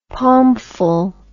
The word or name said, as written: palmful